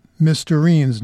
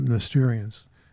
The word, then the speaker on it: Mysterines: Remember, it's Mysterines, not, it rhymes with tangerine, not Mysterians. It's like right next to it, tangerine, so I don't sound like an idiot.